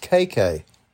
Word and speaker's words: Cecca: And it's pronounced KK. – KK? C-E-C-C-A? – Uh-huh. – Okay, well, it's I was reading about it, and I saw the pronunciation. I went, oh, that's weird. Okay, but it's KK.